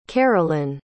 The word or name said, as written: kerilyn